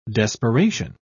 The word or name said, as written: desperation